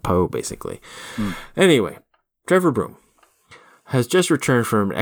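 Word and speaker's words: Bruttenholm: His last name is spelled Brutenholm, but we're assured in a nice text box that it's pronounced Broom,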